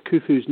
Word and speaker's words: Khufu: in the context of Khufu's name, it's actually K-H. It's pronounced the same way as we would say Loch in Scotland.